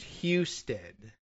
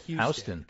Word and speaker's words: Husted: Apparently, the local pronunciation is Husted. – Husted. Houston. – Yeah, right. So, yeah, like the like Houston, but without the O, but then every once in a while you run into a H-O-U that's pronounced Huston anyway.